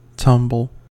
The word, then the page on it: tumble: American English